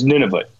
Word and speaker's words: Nunavut: And you've said, by the way, you pronounce Nunavut, none of it. And that embarrasses me as a Canadian. I don't know how to pronounce a very key part of my own country. I always hate it when people, you know, mispronounce or don't know where New Brunswick is. And so what is the correct pronunciation? Is it none of it? – It's Nunavut.